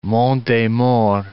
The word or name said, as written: Maures, Monts des